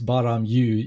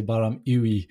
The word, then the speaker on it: BAA-RAM-EWE: Okay, you're I'll, actually I'll, one of the first ones that said it right. It's BAA-RAM-EWE. You're right. Most people say Baram Uwe, and I'm yeah.